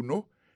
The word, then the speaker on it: know: Knowledge, c'est K-N-O-W-L-E-D-G-E. Knowledge, ça veut dire connaissance. Il sortit du verbe to know, qui veut dire savoir. To know, on a songé que c'est K-N-O-W. Alors ça, que je ne vais pas prononcer K, c'est parce que K, il est silencieux.